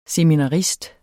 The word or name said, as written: seminarist